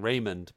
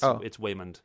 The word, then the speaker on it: Waymond: I thought they were mispronouncing Raymond, but it's Waymond.